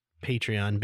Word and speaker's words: Patreon: But I'm pretty sure it's Patreon because it's like a patron.